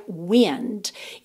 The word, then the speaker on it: wind: Atenção para a pronúncia desse verbo wind, porque a palavra se escreve exatamente igual a wind, vento, W-I-N-D. Só que o vento a gente pronuncia wind. E esse verbo aqui, wind.